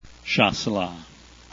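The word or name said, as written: Chasselas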